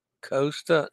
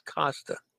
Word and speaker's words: Costa: Costa, not Costa. Costa. Wow. I've been saying that wrong all along.